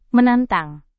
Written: menantang

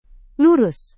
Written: lurus

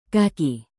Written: kaki

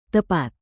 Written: tepat

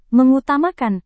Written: mengutamakan

